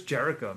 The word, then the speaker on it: Jericho: We no longer have Chris Kegaro. I said that wrong. Chris Kegaro. You know, it's you know, he's back to being Chris Jericho, man.